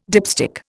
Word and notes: dipstick: Indian

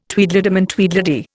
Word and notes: Tweedledum and Tweedledee: Indian

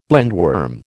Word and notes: blindworm: American English

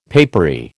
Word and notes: papery: American English

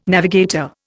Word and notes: navigator: Indian

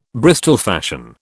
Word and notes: Bristol fashion: British English

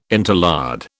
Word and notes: interlard: British English

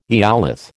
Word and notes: eolith: American English

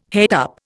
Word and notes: het up: Indian

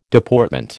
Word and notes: Deportment: American English

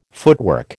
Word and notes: footwork: American English

